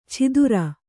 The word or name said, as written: ಛಿದುರ